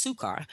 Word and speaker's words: Sychar: Then cometh he to a city of Samaria, which is called Sychar," actually pronounced Sukar.